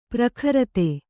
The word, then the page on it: ಪ್ರಾಕೃತ: kannada